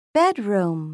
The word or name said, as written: bedroom